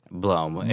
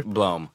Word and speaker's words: Blohm: Blohm. It's Blohm. All right. Yeah, Blohm. Nobody, it's like blow with an M. – Blow it. Okay. – Blohm.